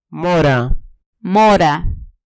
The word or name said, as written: mora